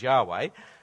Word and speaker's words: Yahweh: Those four letters, YHWH, which I'm pronouncing as Yahweh, they are the name by which the living God revealed himself in the Old Testament to the Israelites.